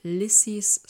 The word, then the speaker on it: Lissy: Ja, wir haben auch erstmal die ganze Zeit hingehört, so okay, wie wird es jetzt wirklich ausgesprochen? Lissy. Wobei die Figur heißt Lisette, wird sonst aber auch manchmal Liza genannt oder eben Lissy.